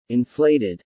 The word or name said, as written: inflated